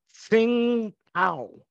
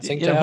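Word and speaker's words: Tsingtao: It's called Tsing Tao.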